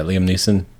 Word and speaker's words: Liam Neeson: Yeah, Liam Neeson.